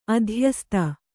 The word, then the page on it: ಅಧ್ಯಸ್ತ: adhyasta